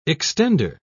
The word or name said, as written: extender